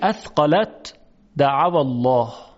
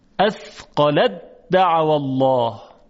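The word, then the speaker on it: أثقلت دعوا الله: و و دیگری اثقلت دعوا الله. اثقلت دعوا الله. میبینیم که حرف ته دو نقطه اصلا در تلفظ حذف میشه، فقط دال تشدید میگیره. اثقلت دعوا الله.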